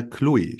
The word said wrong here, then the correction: Cluj: Ich hoffe, ich habe die richtig ausgesprochen. – Jetzt habe ich nicht verstanden. Ah, Chloe, äh, Glusch meinen Sie? Klusch, okay, Cluj heißt es, genau.